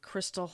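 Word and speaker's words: Christal: My Instagram handle is I am Crystal Hall.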